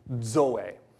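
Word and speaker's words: Zoe (ζωή): The other word is zoe. D-Z-O-E. Zoe. All right? Some people write it zoe, but it's pronounced zoe.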